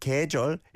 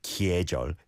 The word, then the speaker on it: 계절: With 계절, it is spelt with a 여이, the 계, 계. But you don't overpronounce that like that. 계절. It's just like 계절, 사계절, 사계절. Four seasons.